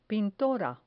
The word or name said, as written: pintora